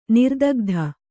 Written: ನಿರ್ದಗ್ಧ

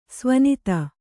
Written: ಸವಿನೀತ

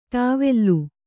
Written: ಟವಲ್

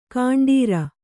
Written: ಗಂಡೀರ

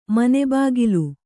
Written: ಮನೆಬಾಗಿಲು

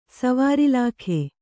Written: ಸ್ವರ್ಲೋಕ

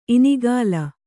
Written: ಇನಿಗಲೆ

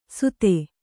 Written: ಸುಧೆ

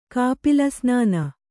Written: ಕಾಪಿಲಸ್ನಾನ

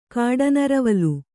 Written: ಕಾಡನರವಲು

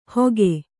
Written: ಹೊಗೆ

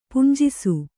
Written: ಪುಂಜಿಸು

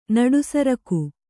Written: ನಡುಸರಕು

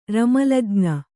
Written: ರಮಲಜ್ಞ

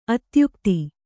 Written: ಅತ್ಯುಕ್ತೆ

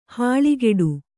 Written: ಹಲ್ಕಡಿ

